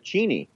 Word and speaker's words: Cheney: there was a huge uh, confrontation with Tim Russert on, on Meet the Press with Dick Cheney, where apparently everyone in Wyoming pronounces it Cheney. – Cheney. I'm not making it up.